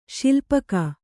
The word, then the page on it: ಶಿಲ್ಪಕ: śilpaka